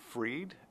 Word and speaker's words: Freed: Can you say your name so I pronounce it correctly? – Sure. It's Doug, D-O-U-G, Freed, F-R-E-E-D.